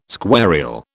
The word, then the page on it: squarial: British English